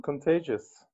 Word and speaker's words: Contagious: Contag hur säger man, uttalar man det? – Contagious.